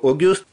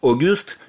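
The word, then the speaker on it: Auguste: Дело в том, что французское имя Огюст пишется через АЮ, которое произносится как О.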